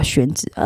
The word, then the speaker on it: 宣纸: 刚才我跟你们讲 color chart 的故事，color chart 就是，他不会用，那谁啥，除非是这一行的，不然谁会知道说宣纸是叫宣纸？宣纸你知道拼法还是什么吗？X，我们台湾人都讲 X 嘛，其实要讲 X U A N 嘛，他那发音成宣纸，可是 X 在美国人就发不出那个音啊，那就是大陆人发成 X 的音啊。